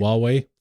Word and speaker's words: Huawei: And then there's Huawei, I think is how you pronounce Huawei. – It. – Huawei. – Thank you very much.